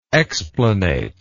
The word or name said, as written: explanate